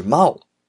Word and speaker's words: 真央: 因为真央的日本发音呢是猫，用英文字母标识出来啊就是 M A O 是猫的发音，所以呢，我们国内喜欢花样滑冰的朋友啊，把他们两个并称为金猫时代。那这一次富士电视台的特别节目，请到了浅田真央猫强的姐姐浅田舞。